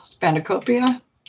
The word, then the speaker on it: spanakopita: Well, I just pulled up my recipe for the spanakopita.